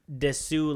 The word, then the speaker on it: desu: You know, when when you're a kid, you say desu like that. You know, because you pronounce the u at the end of des,